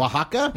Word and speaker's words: Oaxaca: And the way to remember how to pronounce Oaxaca is that it rhymes with Shaka. Because at Ikolu, they have a smoky mezcal drink called the Oaxaca Shaka.